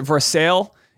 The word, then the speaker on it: Versailles: So anyway, if anyone wants to get a job at Kenny's uh, Kenny Troutt's um, uh, WinStar Farm in Versailles, Kentucky, is it Ver- in France? It's Versailles. I think they pronounce it Versailles. Either way. It should be pronounced Versailles.